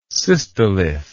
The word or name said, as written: cystolithic